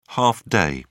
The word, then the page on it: half day: British English